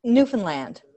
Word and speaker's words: Newfoundland: It's like understand Newfoundland or something like that.